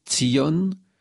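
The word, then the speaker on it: tzion (ציון): Le mot sillon, en hébreu prononcé tzion, signifie en premier lieu le mont, la montagne.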